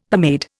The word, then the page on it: pomade: Indian